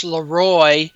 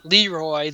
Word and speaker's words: Leroy: Like, Every Second Counts, Bridget Leroy is pronounced Leroy the French way, not Leroy the Southern way.